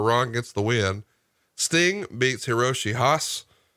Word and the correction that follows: Hase: or I'm just butchering all these Japanese names. Sorry. – Hiroshi Hase.